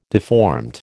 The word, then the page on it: deformed: American English